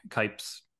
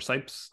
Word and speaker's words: Cipes: Yeah, and then rounding out the cast, we have Greg Kipes or Sipes. I'm not entirely sure about the pronunciation. I think it's Sipes. Voice of...